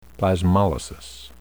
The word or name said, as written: plasmolysis